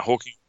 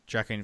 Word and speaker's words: Joaquin: Hawking? Is it Hawking Phoenix? – Joaquin Phoenix, yeah. – Isn't it pronounced Hawking? – Well, nah. Hawking. Joaquin. Joaquin. – Joaquin Phoenix. – Yeah, Joaquin.